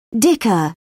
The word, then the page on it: dicker: British English